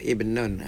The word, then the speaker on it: ibn: Tapi bin tu ibnun. Ada alih benar dia buwe. Jadi tu lebih-lebih bin. Bacanya ibn, ibnun. Jadi orang ini ikhra ni.